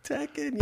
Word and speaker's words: Tekken: I think I'm saying that right. Yeah. – Tekken, yes.